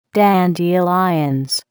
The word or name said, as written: dandelions